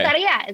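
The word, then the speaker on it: Lotería: Loteria.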